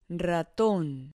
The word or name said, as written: raton